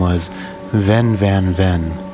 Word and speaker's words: Venn: But if it were a German name, it would be pronounced Fenn.